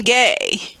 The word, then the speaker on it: Gay: Black alums of Stanford from class of 92, fellow alum, black alum, class of 92, Claudine Gay, which rhymes with gray, but anyway.